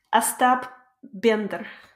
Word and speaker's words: Остап Бендер: Остап Бендер.